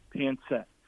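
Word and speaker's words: pancit: And so just as a gift, they brought in this box, and it's a disc called Pansett. It's P-A-N-C-I-T, Pansett. And Pansett, and it can be spelled with an S too.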